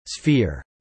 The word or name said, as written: sphere